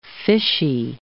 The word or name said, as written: fishy